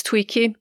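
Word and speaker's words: Tuichi: And I really hope I pronounced it correctly. I checked it out and they said it's Tweaky, the river.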